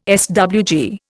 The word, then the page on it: SWG: Indian